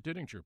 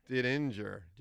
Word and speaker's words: Didinger: We always pronounce it Didinger, but I found out later that we've been mispronouncing it for generations. It should be we say Didinger, but we're actually pronouncing the G twice, yep. Which is probably incorrect. We should be Didinger.